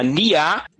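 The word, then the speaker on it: Nyaa: É, se chama Nia SE. Como é que se escreve CNA? É, é N-Y-A-A. Joga NIA.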